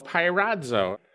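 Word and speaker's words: pyrazo (πειράζω): that's a word in Greek, it's pronounced pyrazo.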